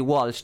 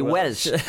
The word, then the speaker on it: Walsh: I think I said Walsh there, even though Welsh, we pronounce it Welsh back in Mayo as well.